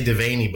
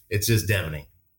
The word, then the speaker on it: Devaney: You know, everybody wants to say Devaney, but there's no A in there. So it's just Devaney.